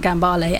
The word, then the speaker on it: Gambale: Justin, who I was in the band with, got a record which was Frank Gambale, and he mispronounced it Frank Gamble.